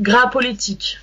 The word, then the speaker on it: Grasse Politique: How do I pronounce the name of the group? – It's pronounced Grasse Politique. – Grasse Politique.